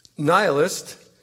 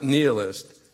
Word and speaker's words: nihilist: Dear Volodya, nihilist is pronounced the way I pronounce it, not nihilist. See any dictionary.